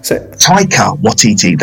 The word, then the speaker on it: Taika Waititi: Taika Waititi. That is it. That is the first A is silent. Yeah, and respect that, please.